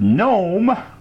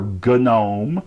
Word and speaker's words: GNOME: It came with GNOME, or GNOME, depending on how you want to pronounce it, 3.2, and virtualization enhancements.